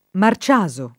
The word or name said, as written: Marciaso